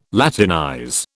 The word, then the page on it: Latinize: British English